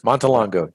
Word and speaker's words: Montelongo: Montelongo.